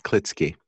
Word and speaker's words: Klitsky: Almost. It's with a K, Klitsky.